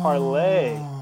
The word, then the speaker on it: Parler: Parler. – Yeah. Oh, dude, well, yeah, but it's pronounced Parlay. Because that means Parler,